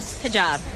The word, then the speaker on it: hijab: I know I'm going to mispronounce, a hijab, is that? – Hijab. I'm wearing hijab, yeah.